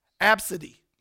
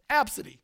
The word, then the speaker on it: Abcde: It's pronounced, I had to write this down, it's pronounced Absidy. It's how she wants it pronounced, Absidy, A-B-C-D-E, right?